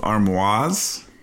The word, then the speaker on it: armoire: You say both armoires? – You say the word. – Both of my armoires. – No, just say just the word. – Armoire. – Armoire. The emphasis is on the second syllable.